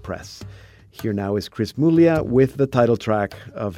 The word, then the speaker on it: Muglia: And just be sure that Muglia is spelled M-U-G-L-I-A, Muglia, the Italian, but he pronounces it Chris Muglia.